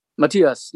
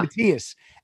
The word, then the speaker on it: Matthias: Matthias, yeah. – Matthias.